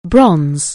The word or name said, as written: bronze